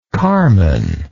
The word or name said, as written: carman